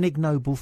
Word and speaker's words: ignoble: You see, I had an ignoble thought today. – Ignoble. – It's the opposite of noble. You do pronounce the G, I believe.